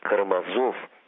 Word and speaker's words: Карамазов: Понятно, что Карамазов, а точнее Карамазов, так произносят эту фамилию итальянцы, лишь новый антураж для старого, доброго, неаполитанского фарса.